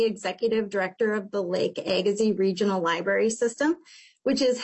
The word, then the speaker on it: Agassiz: And I'm sorry, I've never been confident in my pronunciation of Lake Agassiz? – Agassiz. – Yeah. Oh, well, I can probably remember that.